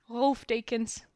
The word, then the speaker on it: Rolf Dekens: In Holland you say it, yeah, like Rolf. It's a German name actually, but I live in the Netherlands. – Okay, I can pronounce that. Rolf Dekens.